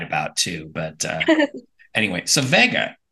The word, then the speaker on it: Vega: Oh, Vega.